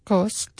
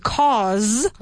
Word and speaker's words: cause: Should I pronounce the final T or not? No. Because cost is costar. It costs, I don't know, a bottle of water costs 50 cents. Okay? But to cause...